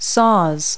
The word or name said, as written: saws